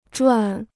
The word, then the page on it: 转: zhuǎi